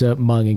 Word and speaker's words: Hmong: I know it's Hmong in case Tony butchers it. I know how to say it.